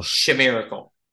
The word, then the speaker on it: Shaimiracle: I'm going to spell it. – Yeah. – S-H-A-I-M-I-R-A-C-L-E. – Wow. – Shamiracle. – Shamiracle, you're right. That's Shamiracle. Exactly how you would say it.